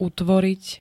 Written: utvoriť

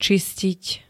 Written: čistiť

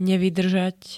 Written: nevydrzat

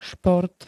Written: šport

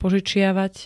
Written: požičiavať

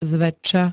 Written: zväčša

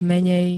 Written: menej

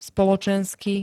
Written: spoločensky

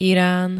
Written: iran

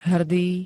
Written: HRDÝ

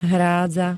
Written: hrádza

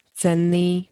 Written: cenný

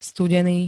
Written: studeny

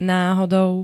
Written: nahodou

